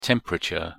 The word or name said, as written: temperature